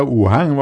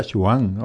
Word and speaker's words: Wuhan: me decía que es la pronunciación. – Así es como lo he visto yo por ahí, que yo me creí que era Wuhan o algo así, y parece ser que se pronuncia Chuján o Suján.